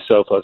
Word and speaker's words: Sophos: S-O-P-H-O-S. Correct? – Yep. – And that's pronounced Sophos. – Yeah. – S-O-P-H-O-S.